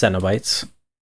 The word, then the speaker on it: Cenobites: So first of all, it's pronounced Cenobites.